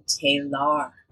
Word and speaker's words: K'Ehleyr: Shows up and she says, I am Kalar.